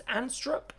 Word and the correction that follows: Anderstorp: which is the racetrack again. I have no... Anderstorp, sorry, I knew my pronunciation was not going to be correct on that.